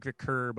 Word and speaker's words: McVicker: Well, yeah, it's, it's McVicker, but it's spelled car.